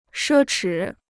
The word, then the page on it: 奢侈: shē chǐ